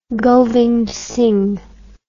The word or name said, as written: govind singh